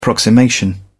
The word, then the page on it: approximation: British English